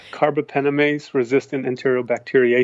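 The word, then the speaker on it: carbapenem-resistant Enterobacteriaceae: And certainly you see outbreaks of bacterial superbugs like, now I'm going to pronounce it wrong, but carbapenem-resistant entobacteriaceae C, something like that? – Yep. Yep. Carbapenemase resistant enterobacteria, ACA. – Yeah, ACA. Nearly got it right at the end.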